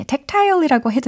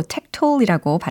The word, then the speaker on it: tactile: 연민으로 가득 찬 그늘은 offers a tactile 네, tactile이라고 해도 되고 tactile이라고 발음을 하셔도 괜찮아요.